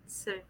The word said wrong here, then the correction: Sven: Sven, yeah. – I don't think you actually pronounced the E.